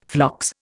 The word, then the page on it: phlox: Indian